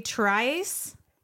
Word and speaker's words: Tryce: I'm in Trinity Tryce.